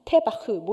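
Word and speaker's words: 테바흐 (תבה): 근데 히브리어는 왼쪽에서 오른쪽으로가 아니라 오른쪽에서 왼쪽으로 읽는 거예요. 그래서 저렇게 생긴 그 우리가 고구마에서 마자로 읽을 것 같은 저거를 트 발음을 내서 테, 그다음에 이렇게 그라우처럼 생긴 게 브라는 그 제가 이거를 우리 제가 아는 주이시 아줌마한테 말했더니 제가 자기보다 히브리어 잘 읽는다고 막 칭찬해 주더라고요. 어쨌거나 고구마가 아니라 테바흐 뭐 이런 식으로 읽어내요.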